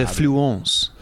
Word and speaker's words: Fluence: The Fluence. – Oh, sorry. Yes, correct pronunciation. Very good.